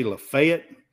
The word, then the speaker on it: Lafayette: A lot of people say Lafayette, but Lafayette is the proper pronunciation. – Proper French pronunciation, right.